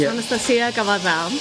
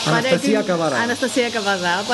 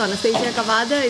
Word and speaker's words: Anastasia Kavada: Well, in Greek, it's Anastasia Kavada. Anastasia Kavada, but I do not expect people to have the Greek pronunciation, so Anastasia Kavada, it's fine, that's how I'm known here.